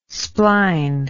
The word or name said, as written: spline